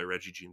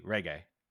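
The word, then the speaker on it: Regé-Jean: Reggie Jean Page or Reggie Jean Page. I'm not sure how to say. Yeah. – I'm sorry. No, it's a hard G. Reggae.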